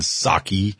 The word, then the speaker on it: Psaki: And after all, Jen Psaki, the P is silent, just like the P in President Biden Psaki.